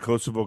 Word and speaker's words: Kosovo: The Kosovo conflict. Am I saying that right, Beck? – Yeah, not my mom and dad, but my cousins, my uncle.